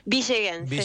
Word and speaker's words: villeguense: villaguense, ¿se dice? ¿Cómo se dice? – Villeguense, se dice muy villeguense. – Bien. Lo has dicho muy bien.